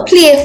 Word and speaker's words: Plie: You spoke about, I think, is it Pile for the Arts? Did I pronounce play. – It? Play.